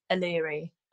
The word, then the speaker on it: Aleri: I order a coffee, they ask for my name and I say Aleri, and you could just see panic. They glaze over and they go, is that A-L? I just smile and nod. I'm like, yeah, whatever. Spell it how you want. I've had Hillary, Allery, Celery.